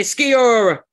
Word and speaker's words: Sciorra: Sciorra. – Yeah, that sounds more. Hey, Sciorra.